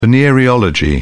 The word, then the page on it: venereology: British English